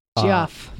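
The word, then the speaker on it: Geoff: It's G-E-O-F-F Smart. – Jeff. That's how I always say it.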